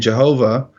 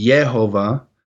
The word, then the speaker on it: Jehovah: They were using that stuff and they forgot that the J's were pronounced like Y's and the W's were pronounced like V's. And so that's why over here in America you have people saying Jehovah, which that's it's not Jehovah. It's it, it would be Yehovah, and people saying Yahweh, and it's not Yahweh.